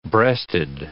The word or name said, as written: breasted